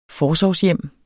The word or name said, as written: forsorgshjem